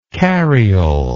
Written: carriole

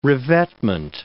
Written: revetment

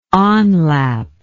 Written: onlap

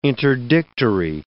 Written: interdictory

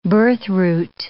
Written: birthroot

